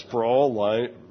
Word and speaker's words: Sproul: I called him Sproul. Sproul, somebody emailed me who heard the Sunday school, says Sproul rhymes with soul.